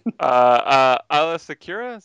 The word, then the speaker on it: Aayla Secura: Aayla Secura, is that how you say her name? – Yeah, good job.